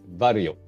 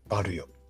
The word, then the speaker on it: Varjo: XR 会議で出ていたデバイス、ヘッドマウントディスプレイ系のデバイスでいうと、 XR4 ですかね、バルヨっていう会社の XR4 が、最近発表されて、その展示が行われておりました。 – バルヨ。なんか難しい字を書きますよね。 VARJO とか。 – そうですね。 J を書いて、ね、よって、 JO をよって読むんで、北欧だったかな。